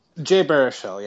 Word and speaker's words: Jay Baruchel: You say Jay Baruchel. Did I pronounce it correctly? I'm not so good. – Jay Baruchel, yeah.